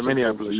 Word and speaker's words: Minneapolis: I just messed Minneapolis. Yeah, you got it. – Yeah, I just met some folks from Minneapolis the other day. I was walking through the neighborhood. Good people. It sounds like Minneapolis is a cool city.